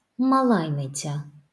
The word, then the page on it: малайниця: malajnicâ